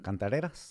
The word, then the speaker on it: cantareras: It's called like can- canteras or can cantareras. – Cantareras. Yeah, yeah, yeah, yeah.